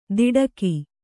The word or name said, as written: ದಿಡಕಿ